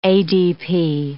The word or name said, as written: ADP